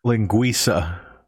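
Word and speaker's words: Linguiça: Now, how do you say it? Linguica? – Linguisa. – Linguisa.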